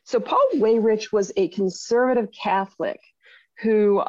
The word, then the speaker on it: Weyrich: I don't know how he, is it Weyrich? How does he pronounce his last name? Do you know? – I don't know. I think it's Weyrich. – Okay, so it's Weyrich.